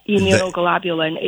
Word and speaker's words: immunoglobulin: The immunoglobulin, is that how you pronounce it? – Yep, immunoglobulin is the molecule.